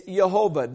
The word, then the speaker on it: Jehovah: It's spelled with a J, pronounced with a Y. It's Jehovah.